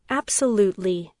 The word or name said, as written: absolutely